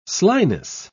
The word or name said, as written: slyness